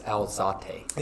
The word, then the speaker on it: Alzate: But Liverpool really couldn't do work because in the 56th minute, Steve Alzate, I don't even know if I say his name right. – I think it's Alzate.